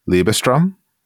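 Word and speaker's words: Liebestraum: Gonzo conducts Rolf at the piano, and a whatnot violinist is playing Liebestrom. I hope I pronounced that well. – Yeah, that's pretty good.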